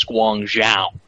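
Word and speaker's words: Guangzhou (广州): I believe the pronunciation is Guangzhou. It's a Zhao sound. Zhao. – Fuck you too.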